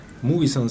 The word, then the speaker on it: Mugison: Se viene Mugison, ¿estoy pronunciando bien? – Mugison, sí, unos islandeses.